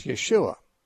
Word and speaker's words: Yeshua: In Hebrew, it's Yeshua.